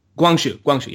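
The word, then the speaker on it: Guangxu: And her nephew, the emperor, who I pronounced as Wang Shu, but I believe that's not exactly correct. – I think you're pretty much right on that, actually. So Guangzhou, yeah.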